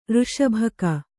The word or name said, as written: ಋಷಭಕ